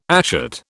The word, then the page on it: ashet: British English